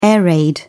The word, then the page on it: air raid: British English